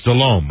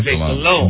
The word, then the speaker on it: Delhomme: Still sometimes hear a guy on, like even on the NFL channel, say, Jake Delhomie. – Mm-hmm. – Delhoma. – When it's Delhomme, right? – Delhomme. – Delhomme.